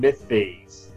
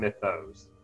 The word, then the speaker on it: Mythos: Apparently it's pronounced the Cthulhu Mythes, not Mythos, apparently.